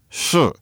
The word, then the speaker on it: shi: It's this one syllable, shi. You can imagine it's spelled S-H-I and it's pronounced shi. You can pronounce shi on four different tones. You can go shi, shi, shi, or shi.